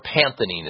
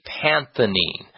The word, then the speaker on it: pantethine: called panthenine is how it's actually prescribed. And it's spelled P-A-N-T-E-T-H-I-N-E, panthenine.